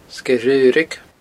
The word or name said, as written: Sgeir Dhraoraig